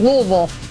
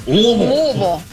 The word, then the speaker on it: Louisville: 24th ranked Louisville leads Villanova 31-10 at the half. We will be in Louisville next week. – You got to say it right. Louisville.